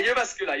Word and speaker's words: Jyväskylä: Can you remember in uh, a Jyväskylä? I don't know if I've said that right. Excuse me. Yeah. Uh, sorry. Y- Jervaskula? Sorry. The, the, there was a NEVZA uh, in Jervaskula. – Yeah, Jervaskula. Yes, I yeah, remember the e- tournament.